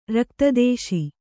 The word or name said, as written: ರಕ್ತದೇಶಿ